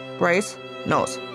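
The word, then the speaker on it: Brasenose: Es wird so ausgesprochen, wie es geschrieben wird, Brasemouth.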